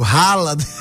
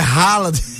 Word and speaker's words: Haaland: Eu vou dizer o nome certo, Haaland. Eu não vou pronunciar o nome dele na Noruega, não. Eu vou pronunciar o nome dele no Brasil. É Haaland.